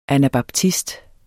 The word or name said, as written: anabaptist